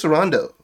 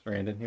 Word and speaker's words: Sarandon: Chris Sarandon. Am I pronouncing that right? – No. It's Chris Sarandon.